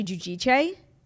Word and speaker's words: Giudice: Yeah, I mean, forever it was Teresa Giudice. And then like years and years later, it came out that like we were all saying it wrong. It's actually Giudice.